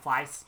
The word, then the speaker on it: Fleiss: Well, I also was in shock when Mike, how do you pronounce his last name? Fleece? Fleece? – Mike Fleiss.